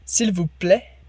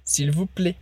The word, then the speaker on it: s'il vous plaît: Lembrando que a gente pode ter uma pronúncia mais aberta ou mais fechada. Então, a primeira mais aberta, s'il vous plaît. Ou o final com som de E, s'il vous plaît. E as duas opções estão certas, é só uma questão de sotaque.